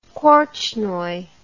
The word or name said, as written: korchnoi